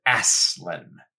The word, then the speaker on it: Aslan: But in one of the letters to children, he said that he pronounces it Aslan.